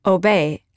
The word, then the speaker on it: Aubais: village of Aubais, pronounced Aubais, as in love, honor, and obey.